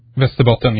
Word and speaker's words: Västerbotten: And they were first seen over part of Sweden, which, I understand is pronounced Västerbotten. – Västerbotten, yes.